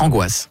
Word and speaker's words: Angoisse: Ça s'écrit comment? – Ça, je connais. Ça. – Angoisse. – Okay. Comme une angoisse.